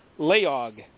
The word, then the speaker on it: Layog: Let me see how they pronounce it. Leog. Leog. – Oh, yeah. That's about what I would expect. – L-A-Y-O-G. Leog.